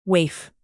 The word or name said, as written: waif